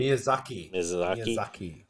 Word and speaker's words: Miyazaki: Elden Ring director, I'm going to butcher this name, Hitaka Miyazaki, limited the scope of George R.R. Martin's contributions to the story. – Miyazaki.